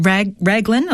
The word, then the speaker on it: Raglan: You're in Raglan.